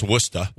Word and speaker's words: Worcester: But it's Worcester. – Worcester, Mass. – Worcester, Mass. And he was all of you guys who try to come over here, you left-hand pitchers, come to Worcester.